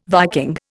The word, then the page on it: Viking: Indian